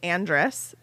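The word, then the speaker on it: Andras: So, like, we know that this is Andrus. Is that how you guys say his name? That's how I say his name. – That's how I say it.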